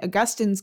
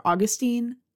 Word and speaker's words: Augustine: And in fact, there's a lot of similarities between this book, structurally speaking, and Augustine's Confessions, or Augustine. I'm going to go on pronouncing it Augustine.